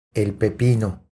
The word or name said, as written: El pepino